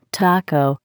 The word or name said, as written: taco